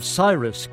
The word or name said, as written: Cyrus